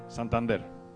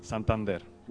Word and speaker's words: Santander: How do you say S-A-N-T-A-N-D-E-R? – What? Santander. – One more time. – Santander.